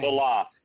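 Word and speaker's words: Saleh: And the coach already said, Sally, I don't know how to really pronounce his name. – Salah.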